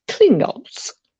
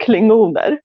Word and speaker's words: Klingons: Alltså nej, nej, nej, nej, nej. uttala det inte på svenska. Alltså visst, okay, okay. eller fine, några säger klingoner. Klingoner, det låter så sjukt lame, eller hur?